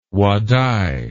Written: Wadai

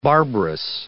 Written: barbarous

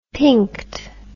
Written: pinked

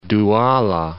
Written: Douala

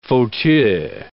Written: fauteuil

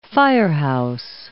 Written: firehouse

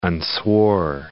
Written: unswore

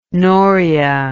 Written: noria

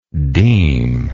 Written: demic

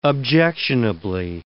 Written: objectionably

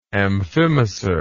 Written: amphimacer

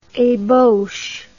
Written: ebauche

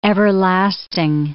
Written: everlasting

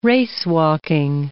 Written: racewalking

